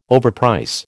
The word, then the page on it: overprice: American English